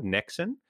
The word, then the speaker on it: niksen: it's definitely a scandinavian word it's called niksen i don't know the exact spelling but that's how it's pronounced um, and it's yep.